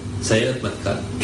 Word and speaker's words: Sayeret Matkal: Yoni had finished his time in the army in a very special unit called Serat. How do you say it? – Sayeret Matkal.